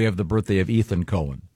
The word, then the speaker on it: Cohen: Cohen. Yeah, you. – This gets confusing because no, not when it's spelled C-O-H-E-N. – It's definitely Cohen.